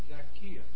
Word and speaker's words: Zacchaeus: I ain't heard it pronounced like that. I've always called it Zacchaeus. Is it Zacchaeus. – Zacchaeus. Yeah, in the Greek text, the accent is on the Epsilon.